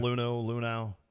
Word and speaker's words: Luhnow: Did I say that right? Luhnow? – Yeah. – Luhnow.